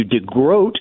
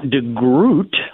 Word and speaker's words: DeGroat: Yeah, I always have to make a little note here, brother, to call you DeGroat, not DeGroot, like a groat, like a boat.